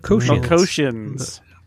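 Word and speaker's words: Melkotians: How did the Melkortians, I don't even know how to say it. – Melkosians. – There you go.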